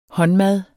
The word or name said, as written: håndmad